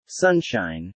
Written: sunshine

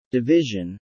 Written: division

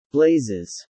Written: blazes

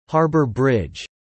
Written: Harbour bridge

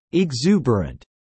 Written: exuberant

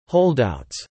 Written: holdouts

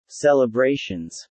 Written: celebrations

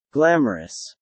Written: glamorous

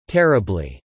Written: terribly

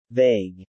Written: Vague

